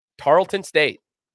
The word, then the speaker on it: Tarleton State: Tarleton State, right? – Yeah. – Okay. I don't know if I'm mispronouncing that or not. – No, that's right.